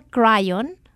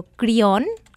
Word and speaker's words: Kryon: Y el nombre de este ser es Kryon, con K, K-R-Y-O-N. Y le doy el nombre por si usted lo quiere investigar. Se llama Kryon o Kryon, depende, en España le llaman Kryon, aquí le dicen Kryon, pero se escribe K de kilo, R-Y-O-N.